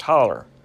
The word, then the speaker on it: hollow: Appalachian English also places an er sound at the end of a word with a long O. For example, hollow, a small sheltered valley, is pronounced holler.